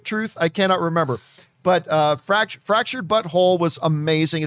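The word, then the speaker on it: Fractured But Whole: And I don't know if you guys have played either of their video games, Fractured Butthole. It's actually Fractured Butthole, W-H-O-L-E, but it's pronounced Fractured Butthole.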